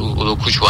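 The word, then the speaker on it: Ugochukwu: Udokuçu diye okunuyor galiba. Udokuçu aynen. Biraz ismi karışık.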